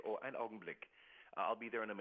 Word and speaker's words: Ein Augenblick: The German term Ein Augenblick.